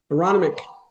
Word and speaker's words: Aronimink: I never say it right. That golf course in Philadelphia there. Aronimanak. – Aronimink. – Say it again. – Aronimink.